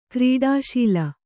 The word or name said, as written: ಕ್ರೀಡಾಶೀಲೆ